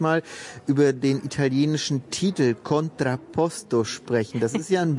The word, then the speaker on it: Contrapposto: Ja, du hast ihn auch so wunderschön italienisch gerade ausgesprochen, Contrapposto. Und auch wir beide, stehen hier auf einem Standbein und auf einem Spielbein.